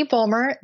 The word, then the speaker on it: Bulmer: My name is Katie Bulmer. That's Fulmer with a B. You said it right, but no one else can ever say it right.